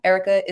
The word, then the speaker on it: Erika: Erica is with a K.